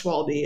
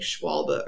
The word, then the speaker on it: Schwalbe: I was told it's not pronounced Schwalbe. It's like Schwalbe or something. Schwalbe. Like that.